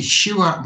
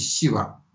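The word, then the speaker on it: Шива: И можно сказать и «щива», можно сказать и «сива», в зависимости от штата, северный или южный штат.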